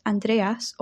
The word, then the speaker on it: Andreas: In Greek, his name is pronounced Andreas or Andraos.